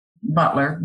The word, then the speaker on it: Butlar: Well, let me first say that um, my name is spelled J A N I S Butlar, B U T L A R, not J A N I C E.